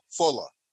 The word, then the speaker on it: Fuller: And it's Arthur Fuller, F-U-L-L-E-R. – Oh, Fuller. I'm sorry. I said it wrong.